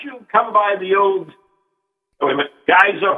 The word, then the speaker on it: Geiser: It's Joe Geyser Hotel, right? – Pardon me? – Joe Geyser Hotel? – Geyser. It's spelled G-E-I-S-E-R. It's pronounced like the geysers in Yellowstone, but it's spelled G-E-I-S-E-R.